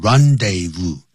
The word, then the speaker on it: rendezvous: The Moulin Rouge was the rendezvous, 这念成 rendezvous, du high life.